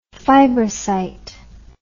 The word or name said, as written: fibrocytic